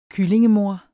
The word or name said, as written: kyllingemoder